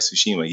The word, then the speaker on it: Tsushima: And I got back into playing Ghost of Tsushima. Did I say that right? Tsushima, yes.